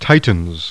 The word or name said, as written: Titan